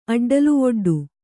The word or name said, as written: ಅಡ್ಡಲುವೊಡ್ಡು